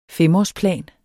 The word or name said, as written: femårsplan